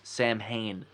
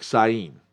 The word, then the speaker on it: Samhain: Samhain. – First of all, pronounced incorrectly. – Wow. – Yeah, it's like Sain.